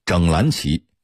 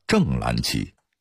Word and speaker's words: 正蓝旗: 由于早年间八旗在京畿各地都有驻军，所以留下了镶红旗、整蓝旗、整黄旗、西二旗等地名。现在镶红旗写成了厢房的厢，其实应该写成厢边的厢。现在有些人不懂旗意，整蓝旗给念成了正蓝旗，连公交车报站也发这个音，看上去一点错都没有，其实是大错特错了。